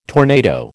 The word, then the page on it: tornado: American English